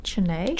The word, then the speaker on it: Chennai: from northern Maine to Chennai, India. Nice to have you in here. – Thank you for having me. – Did I pronounce that correctly? Chennai? – Chennai? – Chennai? – Yeah.